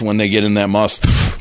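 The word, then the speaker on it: musth: They blame the elephant's aggressive behavior on the fact that he was in a heightened state of sexual tension known as must. – What? – Yeah. Must. You don't pronounce the H? – I don't think so.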